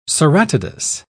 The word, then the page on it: ceratodus: British English